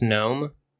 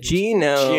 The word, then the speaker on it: gnome: You said G-Gnome. – G-N-R. – It's spelled G-N-O-M-E, but it's pronounced gnome. – Yeah. You're... – It's not pronounced G-Gnome.